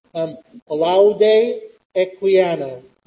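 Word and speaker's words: Olaudah Equiano: So, first of all, how the hell do we pronounce his name? Olaude Equiano. Okay, so that would be the correct way of saying it.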